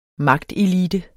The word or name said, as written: magtelite